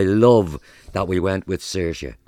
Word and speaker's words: Saoirse: Without further ado, the name is Saoirse, meaning freedom, and pronounced Saoirse.